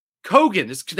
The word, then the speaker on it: Keoghan: Oh, Barry Keoghan. – Keoghan, that's how you pronounce this. Keoghan? – Yeah, I think you don't pronounce the E, if I remember correctly.